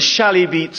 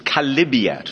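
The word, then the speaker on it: Chalybeate: They call it a shally beat spring. It's actually a mispronunciation of Kalibiat from the Greek, and it's a filthy thing, like a car sump down in the Pantiles.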